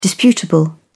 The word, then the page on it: disputable: British English